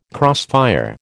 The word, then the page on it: crossfire: American English